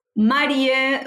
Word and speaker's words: Marie: So it's Marie, which is like the short for Mariela, like Marie underscore Ajras.